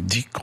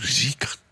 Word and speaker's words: dhikr: Les pratiques soufis, qu'on appelle dhikr ou zikr, comme on prononce en persan.